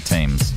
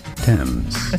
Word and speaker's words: Thames: That's the Thames in London. The Thames. Yeah, a German Navy ship. I said a German Navy ship rolled down the Thames in London. The Thames or the Thames? It's the Thames.